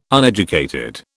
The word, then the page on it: uneducated: British English